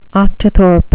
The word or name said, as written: octothorpe